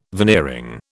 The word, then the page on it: veneering: British English